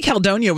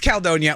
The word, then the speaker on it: Caledonia: I say Caledonia with 2 syllables, you say it with 3.